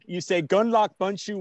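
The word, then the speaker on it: Gundlach Bundschu: Well, if you have a long time and the elevator ride is long enough, you say Gundlach Bunshu Winery.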